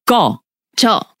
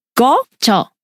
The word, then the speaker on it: 꺼져: emphasis is on the go or the jaw? – Go. – Okay. – One more time. Go. – Go. – Jaw.